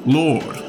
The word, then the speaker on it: Lord: Lord.